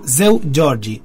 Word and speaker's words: Seu Jorge: Una perlita de este autor, compositor, cantante brasilero, que es Zeu Jorge, se pronuncia, y se escribe Zeu Jorge.